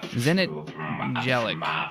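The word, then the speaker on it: Zenit Jeleč: It's spelled Z-E-N-I-T J-E-L-E-C Zenit Jellic.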